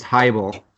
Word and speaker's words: Heibel: It's Heibel, not Heibel or Hable or whatever. So H-E-I-B-E-L, that's how you spell it.